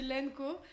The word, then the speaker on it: LNKO: Pour la prononciation, nous on dit... Mais je pense qu'il n'y a que Malak et moi... – Qui le prononçons comme ça. Nous, on dit Lenco.